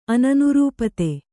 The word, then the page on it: ಅನನುರೂಪತೆ: ananurūpate